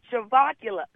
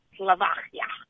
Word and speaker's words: Slovakia: So Slovakia. Am I saying it correctly, Dr. Gina? Slovakia.